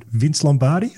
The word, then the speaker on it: Vince Lombardi: Vince Lombardi, I think it is.